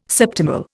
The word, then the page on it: septimal: Indian